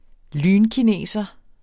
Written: lynkineser

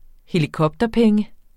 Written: helikopterpenge

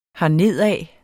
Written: hernedad

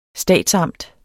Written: statsamt

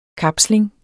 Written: kapsling

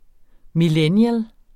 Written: millennials

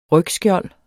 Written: rygskjold

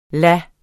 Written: lade

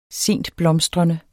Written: sentblomstrende

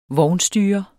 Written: vognstyrer

